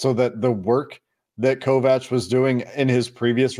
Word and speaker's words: Kovacs: According to Big Ten Network, it's Kovacs. So there's no CH in the pronunciation there.